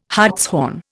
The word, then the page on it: hartshorn: Indian